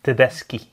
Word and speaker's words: Tedeschi: We werden een aantal afleveringen geleden op gewezen dat wij dat niet correct uitspreken. – Nee. – Correcte Italiaanse wijze is Tedeschi.